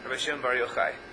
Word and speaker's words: Shimon Bar Yochai: Rabbi Shimon Bar Yochai.